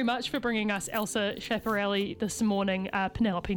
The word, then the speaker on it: Schiaparelli: Schiaparelli. – Schiaparelli.